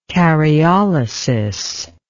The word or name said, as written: karyolysis